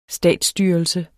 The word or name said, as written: statsstyrelse